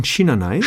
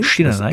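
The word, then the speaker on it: Şinanay: das wird ausgesprochen Shinanai. – Shinanai. Das S mit so einem Haken drunter ist ein mhm. SCH.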